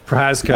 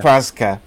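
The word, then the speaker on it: Jiří Procházka: Jiri Prashaka? – Prazka, I think, yeah. – Prazka. I'm glad you're here, to let me know.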